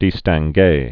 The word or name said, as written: distingue